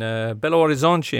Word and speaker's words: Belo Horizonte: They took on June 29th, an amateur USA side in Belo Horizonte, in Minas Gerais.